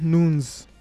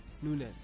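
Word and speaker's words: Núñez: Darwin Nunes. Nunez. – Nunez.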